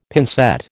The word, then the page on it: pincette: American English